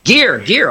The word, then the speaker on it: Gear: Is it Jerry in Florida, or do I mispronounce your name? – Hello. – Yeah. – This is Gear in Florida. – Gear, okay, just the way it reads. – G-E-R-E.